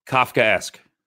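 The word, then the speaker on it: Kafkaesque: How do you pronounce episode 9? – Kafka-esque. – Okay.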